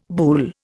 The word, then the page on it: buhl: Indian